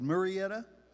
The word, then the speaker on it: Murrieta: There's a city called Marietta. Here it's called Murrieta. We just call it Mayretta in Georgia.